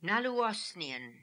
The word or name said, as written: naloo osnian